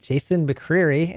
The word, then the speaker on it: McCreary: All right, we are recording with Jason McCreary, and I guess I pronounced that right. Does that sound good? – It's good.